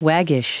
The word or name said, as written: waggish